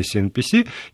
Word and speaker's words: CNPC: мгновенно практически приходит китайская государственная компания CNPC, если я не путаю там произношение. Нет, не путайте.